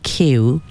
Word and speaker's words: keu: And this word here in the title, keu, which is spelled just K-E-U,